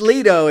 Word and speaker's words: Leto: It's Leto if you really want to get geeky, but it's yeah.